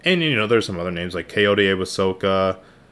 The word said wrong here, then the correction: Awosika: Kayode Awosika.